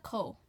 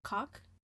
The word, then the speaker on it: Koch: Let's say she's a beautiful redheaded woman back in the early 1930s by the name of Ilsa Koh. – Ilsa Koh. – It's okay. So I was watching a documentary mm-hmm. and they were pronouncing her name Ilsa Koch, but I don't want to be saying cock.